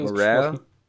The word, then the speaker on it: Morale: Ähm, es geht um Mr. Morale and the Big Steppers. Äh, ich weiß nicht, ob es richtig ausgesprochen ist. Könnte auch. – Es ist richtig Morale, ähm, von Kendrick Lamar.